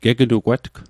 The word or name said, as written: gegnugwatg